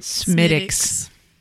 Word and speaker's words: Smithwick's: A ticket includes a full Irish breakfast, a pint of Guinness, or is it Smithwick's? Did I say that right? – Smittick's. – Really? – Smittick's. – Yeah. You said it wrong.